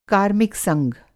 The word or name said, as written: कार्मिक संघ